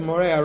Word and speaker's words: Moriah: What does Moriah rhyme with? Moray.